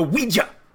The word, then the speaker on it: Ouija: Let's pronounce that the right way. A Ouija board!